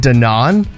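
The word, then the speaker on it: Dannon: Did you know that Dan in yogurt, it's pronounced Danon? – Yes. – I was watching a news... – You did? – Because it's French.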